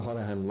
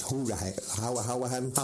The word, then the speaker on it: Hourihan: See, I was surprised at that, because James Bree and Conor Hourihan left, although I'm pronouncing the name wrong, because according to the commentary, it's Hourihan.